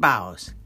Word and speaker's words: bow: I bow, you bow, he, she, it bows. Got it? Now, bow, B-O-W, same spelling, long O sound, bow, the noun.